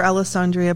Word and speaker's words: Allessandria: So my name is Dr. Allessandria Polizzi.